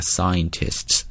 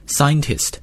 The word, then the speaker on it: scientist: 在这里主语不用讲了，科学家 scientists, 注意一下读这个词，中国同学经常读的时候会读成 scientist, 啊，那这个音不太对啊，是 scientist, 那个 n 要发出来。